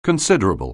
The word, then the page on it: considerable: anglais